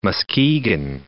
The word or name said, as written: Muskegon